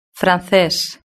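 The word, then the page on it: frances: Spain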